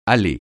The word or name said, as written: allée